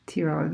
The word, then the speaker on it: Tirol: Tirol, no, no, um, it's correct.